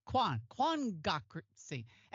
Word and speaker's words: quangocracy: to talk about what she calls a quagocracy. Am I saying that right? Quangocracy.